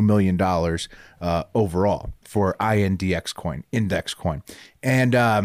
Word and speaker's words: INDX: Him and his wife, Caitlin, of INDX coin, pronounced index coin.